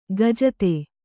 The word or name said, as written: ಕುಜಾತೆ